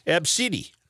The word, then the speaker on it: ABCDE: Tracy Redford told ABC affiliate KABC her daughter's name is ABCDE, which is pronounced Absidy.